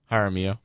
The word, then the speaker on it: Jaramillo: The way you say it sounds like a brand of Jello. – Yes, Rudy Jaramillo. How is the correct pronunciation? – Jaramillo.